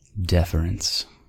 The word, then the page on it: deference: American English